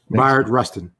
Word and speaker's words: Bayard Rustin: Bayard Rustin.